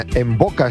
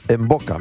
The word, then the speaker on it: Mvoca: Atentos que necesitan o quieren recuperar el brillo de su sonrisa gracias a Envoca Estética Dental. Envoca se escribe M-V-O-C-A. En boca, me siento como Will Spinball. En boca, tiri, tiri, tiri, es en boca.